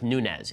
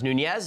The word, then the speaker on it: Nunes: Devin Nunes' name is pronounced Nunes. It's not pronounced Nunez. It's not pronounced Nunes.